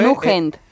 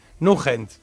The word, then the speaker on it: Nugent: Pues es Ted, T-E-D y luego N-U-G-N-E-N-T. ¿No? N-U-G. – Nugent. – Nugent, sí.